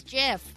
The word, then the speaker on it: GIF: Is it GIF or JIF? – It is JIF.